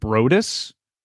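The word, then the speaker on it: Broadus: How do you pronounce his actual last name? Is it Brodus? – Yeah.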